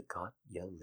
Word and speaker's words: Gotye: It's just like the Gotye lyric, now that I know that that's how it's pronounced.